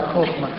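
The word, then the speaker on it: Хохма (חכמה): Но дело в том, что да, хохма. Хохма. Точнее, если произносить по-еврейски.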